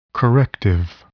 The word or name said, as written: corrective